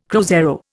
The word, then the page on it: cruzeiro: Indian